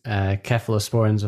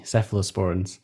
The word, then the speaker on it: cephalosporins: And we were making a joke there because in the UK we pronounce cephalosporins with a hard K, and I know that over in the US it's soft C cephalosporins.